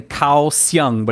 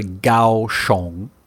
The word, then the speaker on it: Kaohsiung: Yeah, because I'm saying Kaohsiung, but it's actually Kaohsiung.